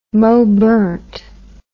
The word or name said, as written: mowburnt